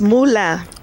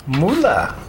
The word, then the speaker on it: Moulin: How do you pronounce it in France? – It's Moulin. – Moulin. – Moulin. – Moulin. – Uh-huh. – Not Moulin. That's money. It's Moulin, I would say like lamb, but without the MB.